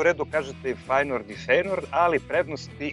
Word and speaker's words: Feyenoord: Ako se negde pojavila varijanta Uh, bez tog E u sredini reči u pitanju je disleks, kako mi volimo da kažemo, to je se najnormalnije izgovara, dakle ili Feyenoord ili Feyenoord i ništa i ništa treće.